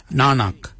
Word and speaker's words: nangag: N-A-N-G-A-G. Nanak. Nanak is your neck. Chin. We say yet. Yet for chin. Yet is spelled Y-E-D.